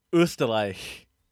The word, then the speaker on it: Österreich: So, how do you say the uh, word for Austria? Österreich. That was pretty good.